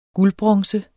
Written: guldbronze

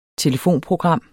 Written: TELEFONPROGRAM

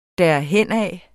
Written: derhenad

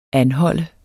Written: anholde

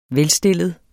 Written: velstillet

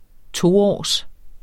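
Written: TOÅRS

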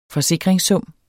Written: forsikringssum

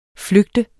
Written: flygte

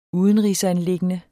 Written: udenrigsanliggende

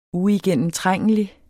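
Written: uigennemtrængelig

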